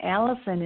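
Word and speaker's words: Allyson: And Christine is spelled C-H-R-I-S-T-I-N-E. And Allison is A-L-L-Y, like yellow, S like Sam, O, N like Nancy.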